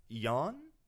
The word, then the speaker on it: yuan: Policies cost 8 yuan? How do you pronounce Chinese currency? – Yuan. – Yuan. 8 yuan.